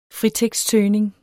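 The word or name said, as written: fritekstsøgning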